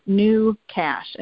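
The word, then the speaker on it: GnuCash: It's called New Cash, and new is spelled G-N-U, Cash.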